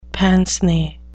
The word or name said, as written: pince-nez